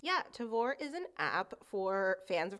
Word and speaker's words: Tavour: It is called Tavor. – Rhymes with before, actually. – Rhymes with before. It's called Tavor. It's actually spelled T-A-V-O-U-R, and